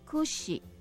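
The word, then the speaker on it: くし: 这个梳子那个的发音哈，kushi 好，那个 ku 就是苦哈，跟苦是同样的音，shi 跟死是同样的音，所以会让人联想到苦跟死哈。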